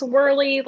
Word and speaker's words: Wehrley: Check me out at Alex Wehrle. Last name's a little difficult to spell, so I'll spell it W-E-H-R-L-E-Y.